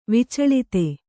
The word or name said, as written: ವಿಚಳಿತೆ